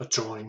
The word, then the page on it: adjoin: American English